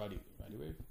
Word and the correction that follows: Rod Wave: I feel like I'm saying that wrong. – No, Rod Wave. – Rod Wave. Right. Okay.